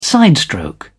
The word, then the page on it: sidestroke: British English